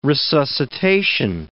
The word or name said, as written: resuscitation